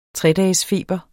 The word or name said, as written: tredagesfeber